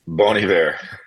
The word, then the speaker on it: Bon Iver: So I wanted to, since I was talking to the source, I wanted to get to the bottom of how you pronounce Bon-Iver, the musician, the artist. And here it is. – Bon Iver. It's okay. Some people say Bon Iver or call me Mr. Bon. That's all good, too.